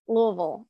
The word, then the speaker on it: Louisville: You're in Louisville.